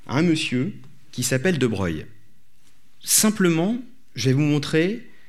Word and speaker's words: De Broglie: Alors, pour les gens qui se posent la question, comme Boris Chenot, pourquoi il s'appelle De Broglie alors que ça s'écrit De Broglie, c'est qu'en fait, sa famille est d'origine italienne et qu'en italien, ça se dit De Broglie.